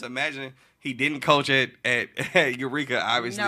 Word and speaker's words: Eureka: So I was at Ladue, and our conference, I think, was Eureka, Ladue, Eureka, UCity, Clayton. – You said it right. All of them.